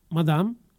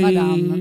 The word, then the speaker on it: Madame: 8 agosto Jamie Tights, 9 agosto Madame.